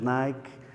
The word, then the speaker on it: Nike: E Nike, que está em muitos dos vossos calçados desportivos, que nós dizemos Nike, com uma pronúncia norte-americana de um grego muito estranho, Nike significa vencer, vitória.